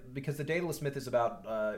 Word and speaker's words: Daedalus: But anyway, so they figure out that she can go to Daedalus.